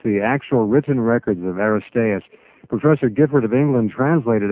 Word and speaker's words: Aristeas: We can now continue with a more detailed description of the temple and Jerusalem as provided by Aristeas. Is that pronounced correctly? – Aristeas.